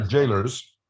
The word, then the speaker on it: Gaoler: How's it pronounced? Is it Jailer's or because it's spelled? It's spelled kind of odd. It's spelled G A G A E. It's G A O. – G A O. – Okay. G A O L E R apostrophe. Jailers or jailers? Jailers.